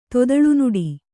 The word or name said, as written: ತೊದಲ್ನುಡಿ